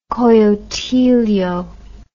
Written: coyotillo

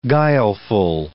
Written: guileful

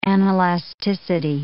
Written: anelasticity

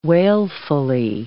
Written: wailfully

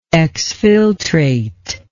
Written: exfiltrate

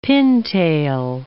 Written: pintail

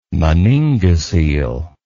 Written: meningocele

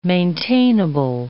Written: maintainable

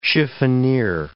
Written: chiffonier

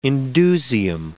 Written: indusium

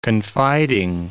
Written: confiding

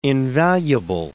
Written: invaluable

